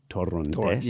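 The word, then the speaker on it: Torrontés: I'm a fan of Torontis, which is an Argentinian From Argentina, actually, in Argentina, they pronounce it Torontes.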